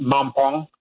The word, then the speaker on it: Mampong: So tomorrow's event is happening in Ekwepim Mampong. That's spelled M-A-M-P-O-N-G. And it's very important that they have Ekwepim because there's another Mampong, which is out in the Asante region, out past Kumasi. It's not that one.